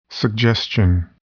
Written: suggestion

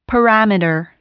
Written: parameter

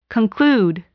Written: conclude

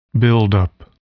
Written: buildup

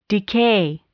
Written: decay

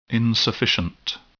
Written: insufficient

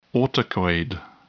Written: autacoid

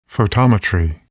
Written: photometry

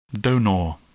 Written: donor

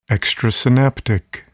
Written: extrasynaptic

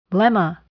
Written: lemma

